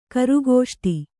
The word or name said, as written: ಕರುಗೋಷ್ಟಿ